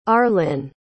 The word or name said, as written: arlin